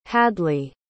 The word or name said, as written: hadley